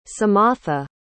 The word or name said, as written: samatha